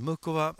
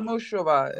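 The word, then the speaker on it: Muchová: Eh, men du, Mukova, så jag säger bara så här, Mukova, eller jag säger ju fortfarande Mukova, nu är vi inne på det här med uttal igen, och Muchova. – Ja, vi ska säga Mushova, eh, Mushova. – Ja, Mushova är det korrekta förstått, sen säger amerikanerna ja.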